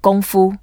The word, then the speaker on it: Kung Fu: Some people normally pronounce it as Kung Fu. Let's get it right. Kung Fu.